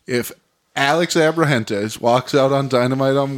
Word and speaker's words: Abrahantes: I didn't say it right. Go ahead and correct me. – Alex Abrahantes. – Yep.